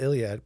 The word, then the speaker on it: Iliad: So it's written in like the Iliad? – I think it's one of them. – Or did I mispronounce it? – No, it's the Iliad.